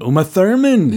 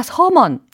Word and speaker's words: Thurman: 근데 이게 한국 사람들은 우마 서먼. 아, 서먼? 네. 스스스스? 서먼. 아, 이거 좀 아니다. 그래요? 이거 좀 다른데요? – 그래서 우리는 이제 우마 thurman 이렇게 연습을 해 둬야 되겠죠? – Ah, uh, but there's no th pronunciation in Korean.